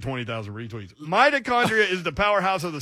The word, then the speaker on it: mitochondria: Mitochondria. Did I say that right? – Yeah. – Mitochondria.